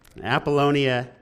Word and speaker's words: Apollonia: Here's how to pronounce it. Amphipolis, Apollonia, and 30 or so to Thessalonica.